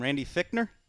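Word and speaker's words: Randy Fichtner: How are we pronouncing the offensive coordinator's name? Randy Fickner? – Yep.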